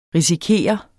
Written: risikere

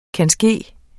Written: kanske

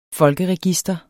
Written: folkeregister

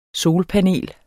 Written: solpanel

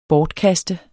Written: bortkaste

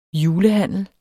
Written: julehandel

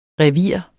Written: revir

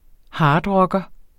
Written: hardrocker

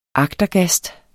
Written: agtergast

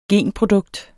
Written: genprodukt

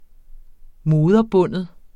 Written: moderbunden

